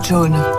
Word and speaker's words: Joan: Je m'appelle John, John Vera. Ça s'écrit J-O-A-N. Mais j'ai horreur qu'on dise Joan.